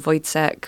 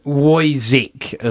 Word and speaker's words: Woyzeck: It is spelt W-O-Y-Z-E-C-K. So Voidsec is kind of how it looks.